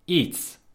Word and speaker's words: iets: So something is iets, spelled I-E-T-S. And in Dutch, when I and E are together, they are pronounced as E, single letter E.